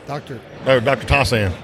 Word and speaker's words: Tossan: Dr. Thiessen? – Dr. Tossan. – Tossan. See, I almost pronounced it right. Tossan. It's like Tarzan.